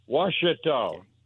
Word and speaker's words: Ouachita: It's Q U A CHA C H I T A Ouachita.